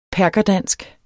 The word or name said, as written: perkerdansk